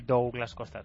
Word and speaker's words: Douglas Costa: Suena un poco extraño, pero es que en Brasil se dice así, Douglas Costa, tal y como se escribe, se pronuncia.